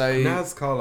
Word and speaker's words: Nazca: But the new band is Nazca Lines. Is that how you say it? NASCAR Lines. – So NASCAR Lines.